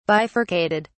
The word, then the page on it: bifurcated: British English